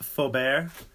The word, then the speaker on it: Faubert: Pronounced Faubert. And it's spelt all jacked up.